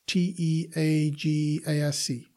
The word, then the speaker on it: Teagasc: How do you spell that? T E A G A S C. Okay. Teagasc. It means Teagasc. knowledge in Irish.